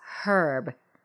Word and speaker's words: herb: However, the French pronounce it herb with a silent H. And Americans sometimes arbitrarily decide how they will pronounce a word in the French style, even if it is an English word. So that's why Americans leave the silent H in herb just as the French do.